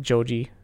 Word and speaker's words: Joji: Joji. – Joji. – Yeah. His real name is George Miller. – Oh, I didn't know that. Joji is how you pronounce his name in Japanese.